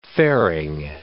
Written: fairing

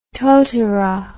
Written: totara

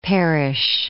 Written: Parrish

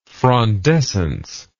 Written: frondescence

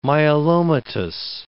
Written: myelomatous

